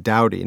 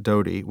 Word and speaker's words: doughty: I guess one was, so D-O-U-G-H-T-Y, which is sort of an out-of-fashion word, and it's apparently pronounced Dowdy, not Dodie,